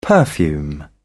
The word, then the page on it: perfume: British English